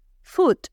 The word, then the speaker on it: foot: Football, porque la palabra pie se dice foot.